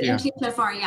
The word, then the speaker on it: MTHFR: Yeah, the MTFHR, yeah. – Which, and we're not cursing at you listening. That's the way to pronounce it. It's like the curse word gene.